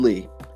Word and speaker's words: Lea: You can find me on Facebook by searching The Imperfect Opinion, or through my personal page, David Lea. And again, the last name is spelled L-E-A.